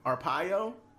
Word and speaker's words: Arpaio: Arpaio? – Yeah.